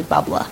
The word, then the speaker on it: bubbler: Oh, when I say bubbler, I'm saying it wrong. It's actually bubbla. Like, we don't put the R's on anything.